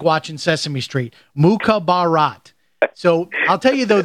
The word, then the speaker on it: Mukhabarat: The book is titled Mukhabarat Baby, My Life as a Wartime Spy for the CIA.